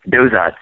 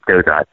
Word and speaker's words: Dozat: We pronounce the T, so it's Dozat St. Marie.